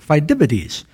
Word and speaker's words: Phidippides: So Phidippides, I knew I said his name wrong, Phidippides. And if you go to college for 8 years, you can get a degree called Phidippides Kappa.